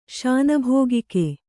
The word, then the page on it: ಶಾನಭೋಗಿಕೆ: śanabhōgike